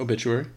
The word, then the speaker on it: obituary: Am I saying that right? – Yeah, obituary.